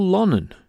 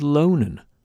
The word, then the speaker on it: Lonan: Next, a piece of music by a band called Lonan, L-O-N-A-N. I don't think they've got any Manx links. I actually heard another folk show presenter pronounce the name as Lonan, but being a Lonan resident myself, we'll stick with the Manx pronunciation.